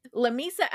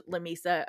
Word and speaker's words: Lamesa: Lamesa, a lot of people have, there's a joke that goes around Lamesa of how do you actually pronounce this? Is it